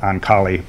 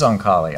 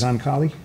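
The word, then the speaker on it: Oncale: Um, I mentioned Price Waterhouse, and then the, the final one is uh, I think it's pronounced actually Ankal, uh, Ankal, but I've always called it Ankali. But you're right. It is Oncali.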